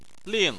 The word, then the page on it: ling: 4